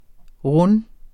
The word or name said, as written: runde